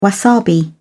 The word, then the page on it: wasabi: British English